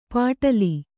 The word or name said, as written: ಪಟಲಿ